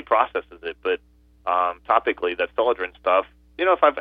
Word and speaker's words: Celadrin: It's Selodrin is spelled C-E-L-A-D-R-I-N, Selodrin.